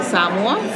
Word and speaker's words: Samoa: Samoa. – Samoa. – Yeah, how do I spell that? – It's spelled S-A-M-O-A. A lot of people say Samoa. – Oh, I was going to say, that sounds like Samoa to me. I've said Samoa all my life. I've been saying the wrong thing.